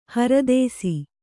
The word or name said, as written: ಹರದೇಸಿ